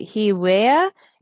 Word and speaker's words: HeWe: And then the last one is I'm not sure if I'm saying it's right, Hewea, H-E-A-W-E-A. How do you say that? – HeWe, healing HeWe. Wearable. – So okay. – It's short for healing wearable.